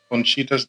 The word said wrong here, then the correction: Cochinita: de Pabil. Mm-hmm. Is that so? – It's Cochinita.